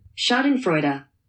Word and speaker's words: Schadenfreude: How do you say that? – Schadenfreude. – Okay. As we're watching the drama and sometimes downright tragedy play out on screen.